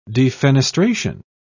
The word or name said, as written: defenestration